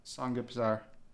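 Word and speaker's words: Samgyeopsal (삼겹살): Samgyeopsal.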